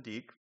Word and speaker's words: Deke: Everybody at Yale knows what you think when you hear that they're in Deke, as they pronounce it on campus.